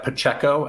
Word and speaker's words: Pacheco: This Isaiah Pacheco, I'm not sure, is that how you say his name? – Yeah, the Rutgers kid.